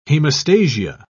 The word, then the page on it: hemostasia: British English